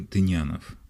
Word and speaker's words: Тынянов: Среди подписавших протест Алексей Толстой, Константин Федин, Юрий Тынинов, Маршак, Михаил Зощенко, Николай Тихонов, Лидия Сейфуллина, Вячеслав Шишков, академики Евгений Тарле, Сергей Ольденбург, Шлиссельбуржец, Николай Александрович Морозов и многие другие. Я уточнил, правильно говорить, Юрий Тынянов.